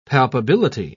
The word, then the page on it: palpability: British English